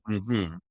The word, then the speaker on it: mmhmm: It's called mm-hmm. It's spelled MMHMM dot app.